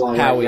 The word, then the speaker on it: Huawei: Huawei or however you pronounce it. – Huawei, yeah.